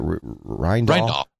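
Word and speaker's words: Rijndael: Rindal.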